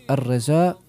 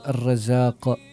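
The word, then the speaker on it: Ar-Razzaq: Pa se često može čuti Allah umjesto Allah, Al-Uddus umjesto Al-Quddus, Ar-Reza umjesto Ar-Rezaq, a razlike su ogromne.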